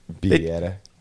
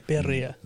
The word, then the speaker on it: birria: It's pronounced Bieta. – Say it again. – Birria.